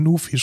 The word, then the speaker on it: Nuphy: Ich meine, es schreibt Nufi, schreibt man es ja, gern. Ja, N-U-P-H-Y.